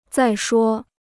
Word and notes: 再说: zài shuō

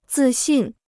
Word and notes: 自信: zì xìn

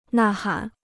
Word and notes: 呐喊: nà hǎn

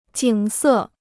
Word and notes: 景色: jǐng sè

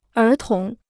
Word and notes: 儿童: ér tóng

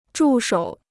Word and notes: 助手: zhù shǒu

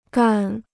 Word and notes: 干: gàn

